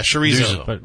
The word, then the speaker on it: chorizo: Are you talking about chorizo? – Yeah, chorizo.